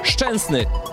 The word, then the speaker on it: Szczęsny: Szczęsny. Okay. Quite okay. Thank you. Szczęsny.